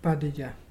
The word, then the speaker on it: Barraja: That is spelt B-A-R-R-A-J-A, but pronounced Badija.